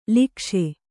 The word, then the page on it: ಲೋಕೇಶ: lōkēśa